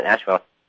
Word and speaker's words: Ashwell: Mr. Sheppard, you know, I wanted to know, how does a lad from Ashwell, England, did I pronounce that correctly? – Yes, that's right, Ashwell.